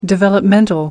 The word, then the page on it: developmental: British English